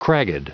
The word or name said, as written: cragged